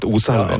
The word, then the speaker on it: Oselver: Hva en Oselver, og det var riktig uttalt, Oselver.